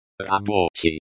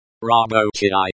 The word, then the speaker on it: рабочий: Рабочий. – Рабочий, working. The stress is on the second syllable.